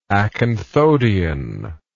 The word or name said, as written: acanthodian